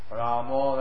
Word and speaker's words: Rama: In Hindi, it's Krishna, Rama, Rama, but in Bengali, they pronounce long A, Ra, and then the shorter is the Ma, Ramo. That's why you hear the word Hare Krishna, Hare Krishna, Hare Ramo. So that's the Bengali is O at the end, if it's a short A.